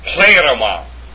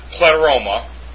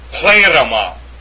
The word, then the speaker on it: pleroma: It's pronounced pleroma. It's not pleroma. The correct pronunciation of the form, the noun form, is pleroma.